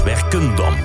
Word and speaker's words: Werkendam: Werkendam, trouwens. Geen R, maar een N van Nico.